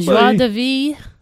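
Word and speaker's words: joie de vivre: Oh, Joie de vie. – Oh, you said it right.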